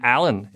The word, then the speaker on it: Alain: I'm so French-Canadian, my name, Alain, is spelled A-L-A-I-N.